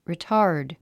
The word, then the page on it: retard: ritάːrd; リィタード